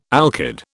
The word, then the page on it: alkyd: British English